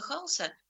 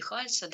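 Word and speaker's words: Hals: А вот как раз Франца Халса, ну или Хальса, да, у нас по-разному произносят, который родился за 20 лет до Рембрандта, вот его как раз именуют живописцем голландской буржуазии 17 века или портретистом одного социального слоя.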